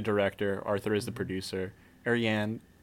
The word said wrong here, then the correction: Ariadne: I always say that name wrong. – Ariadne. Ariadne? – Yeah. I never know if the D or N E is uh, is silent or not.